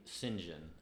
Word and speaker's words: St. John: It's an English name, St. John, and it's spelled like St. John.